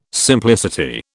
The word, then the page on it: simplicity: British English